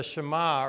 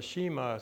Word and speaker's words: Shema: Now, last week, we talked a little bit about the Shema, or the Shema, some people would pronounce it, the Deuteronomy 6:4, where, hear, O Israel, the Lord our God is one God.